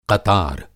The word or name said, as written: क़तार